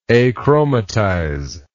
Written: achromatization

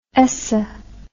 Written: eso

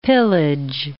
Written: pillage

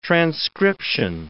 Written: transcription

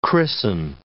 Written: christen

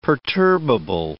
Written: perturbable

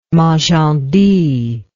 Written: magendie

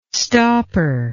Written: stopper

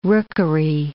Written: rookery

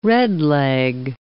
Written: redleg

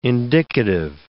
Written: indicative

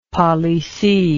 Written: palissy

